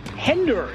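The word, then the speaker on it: hænder: Ooh, hender is how you say hands in Danish, in case you were wondering.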